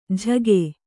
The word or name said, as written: ಝಗೆ